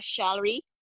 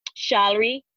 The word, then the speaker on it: Charlery: Yes, my um, my name on social media is author Charlery, author hyphen uh, hyphenated um, Charlery,